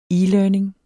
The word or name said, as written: e-learning